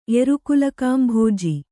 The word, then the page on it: ಎರುಕುಲಕಾಂಭೋಜಿ: erukulakāmbhōji